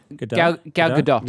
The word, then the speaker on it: Gadot: So it stars The Rock, Ryan Reynolds, and Gal Gadot. I don't know if I'm pronouncing her name right. – Gal Gadot. – Yeah.